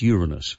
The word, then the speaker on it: Uranus: And also in very wise circles, it's pronounced Uranus.